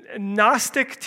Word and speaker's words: Gnostic: It probably won't mean much to any of you, but if you want to look it up, Gnostic teaching with a G in front, silent G, Okay, Gnostic teaching was cropping up all over the place.